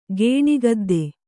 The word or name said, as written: ಗೇಣಿಗದ್ದೆ